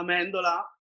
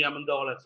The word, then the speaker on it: Amendola: Well, in Italian it sounds uh, amendola, but everybody in the United States call be amendola. So I'm, I'm getting used to amendola too.